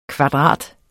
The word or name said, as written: KVADRATERNES